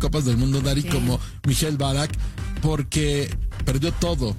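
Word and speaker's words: Ballack: ¿Cómo se dice bien? ¿Mikhail Balak? – Mikhail Balak, sí.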